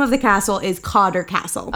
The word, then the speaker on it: Cawdor: Cawdor, C-A-W-D-O-R. – Oh, okay. – Cawdor. – Which throughout the course of its history is spelled three different ways, but it's all pronounced Cawdor. Okay. It's like sometimes it's C-A-U-D-L-E-R.